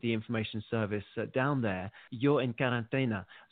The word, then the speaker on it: Yo en Cuarentena: Yeah, you pronounce it well. Yo en Cuarentena.